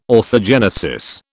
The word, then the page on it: orthogenesis: British English